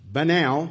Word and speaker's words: banal: Banal, pronounced like canal, banal means cliched or ordinary or commonplace.